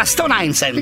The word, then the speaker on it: Stone Island: Questi jeans della Stone Island.